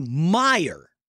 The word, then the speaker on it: Mayer: But this guy, Matthew Meyer, that's how he pronounces John it.